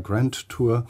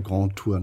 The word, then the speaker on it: Grand Tour: Und ich sage immer Grand Tour. Ja. Aber das ist eben das Schöne, dass man das eben doppelt aussprechen kann. Bei der Präsentation dieses Buches wird gewissermaßen eine Grand Tour nachvollzogen, indem dieses Buch mit Autoren aus unterschiedlichen Ländern in 10 Stationen in Europa präsentiert wird.